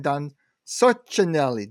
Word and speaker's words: Sorcinelli: All right, and also, of course, the good Dr. Jon Westfall and our good friend Don Sorcinelli.